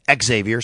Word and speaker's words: Xavier: Xavier, sorry, that's how you say his name. I asked him.